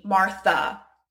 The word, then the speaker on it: Martha: It can also have a schwa sound, i.e. the sound that you hear at the end of my name, Martha.